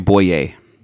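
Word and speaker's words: Bouye: AJ, how do you say his name? Boyu? – AJ Boye. – Boyer. Yes.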